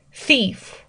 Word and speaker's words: thief: thief，thief，一个是这个 th 的发音会有点难，那第二个是这个长元音的 e 可能发的不准哈。 thief，然后它的这个呃复数形式是去掉 f 加上 ves，thieves，thieves。